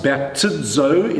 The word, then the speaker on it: baptizo: It's pronounced baptizo in the Greek language.